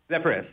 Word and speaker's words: Zephyrus: Eh, I'm pronouncing it right. The Zephyrus, right? – Zephyrus, yeah, perfect. correct.